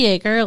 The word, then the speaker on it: Yeager: Is it really Jaeger, like the liquor, like Jägermeister? – It's not spelled the same, but yes, it's pronounced the same.